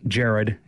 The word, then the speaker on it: Jarrod: Um, uh, uh, Gerard, Jared, excuse me, I think it's Jared is how he pronounces it.